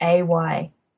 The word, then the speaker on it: Lindsay: It's Lindsay. Silent D and an A.